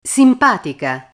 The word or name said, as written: simpatica